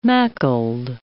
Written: macled

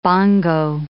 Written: bongo

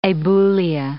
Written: abulia

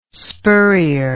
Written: spurrier

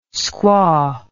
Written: squaw

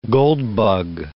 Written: goldbug

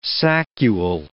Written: saccule

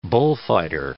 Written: bullfighter

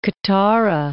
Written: Qattara Depression

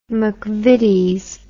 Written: McVitie's

